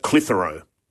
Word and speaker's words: Clitheroe: But the correct pronunciation, Clitheroe.